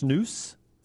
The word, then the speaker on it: snus: Chris says it's pronounced snus. – All right. – That's the way they say it over there.